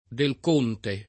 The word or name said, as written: Del Conte